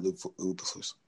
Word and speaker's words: Eberflus: You know, I really like what Matt Eberflus has been able. Well, did I say that right? – Yes, Matt Eberflus.